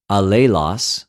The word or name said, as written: ἀλληλος